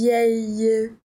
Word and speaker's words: vieille: Aqui a gente tem um som que não existe no português, esse ie. Je suis vieille, formado pela junção dos dois L's, né? Uma dica para ajudar na pronúncia é lembrar do famoso ioiô, aquele brinquedo da sua infância.